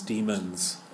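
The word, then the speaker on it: daemon: Yeah, so Philip Pullman's demons in his Dark Materials are partly coming from Blake. Yeah, he actually pronounces it demon, Pullman himself.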